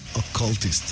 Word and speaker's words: Occultist: Já agora, como é que é pronunciado corretamente o nome da banda? Que eu sou um nabo completo em inglês. – Eu digo Occultist.